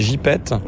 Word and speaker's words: gypaète: L'une des actions que j'ai vues sur votre site, c'est notamment la réintroduction des gypètes. – Oui. – Je crois qu'on dit comme ça. – Oui. – Ça s'écrit gypaète, mais ça oui. – se dit gypète.